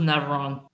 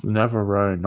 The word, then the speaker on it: Navarone: My greatest movie of all time at the current stage would be either The Battle of the Bulge or Guns of Navarone. – It's Navarone, not Navarone.